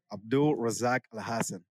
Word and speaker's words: Abdul Razak Al Hassan: Because it seems simple on the surface, Abdul Razak Al Hassan, but there are a lot of opportunities to wrongly emphasize a syllable.